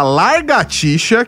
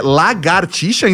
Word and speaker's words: lagartixa: Quero já deixar a menção honrosa pra largatixa, que na verdade é lagartixa e não largatixa, não dá pra largar nada.